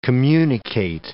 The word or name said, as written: communicate